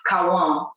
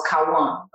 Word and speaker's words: Kawan: So when I was in the States, it was Quan. And then I went back to Brazil, and in Portuguese, it's Kawan. So then I decided when I moved back to the States that I want two syllables in my name. So now I like to be called Kawan or Quan. Kawan, yeah.